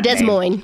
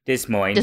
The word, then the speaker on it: Des Moines: Now, Dish was born in Des Moines. I most likely butchered that Des Moines. – Name. Des Moines.